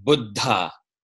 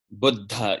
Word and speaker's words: Buddha: But I just remember that story because he always pronounced it very correctly. Buddha, Buddha, you know, with that little pause for the double consonant.